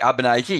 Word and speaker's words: Albanese: Albanese? – Correct. Albanese, yes, correct.